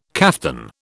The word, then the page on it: kaftan: British English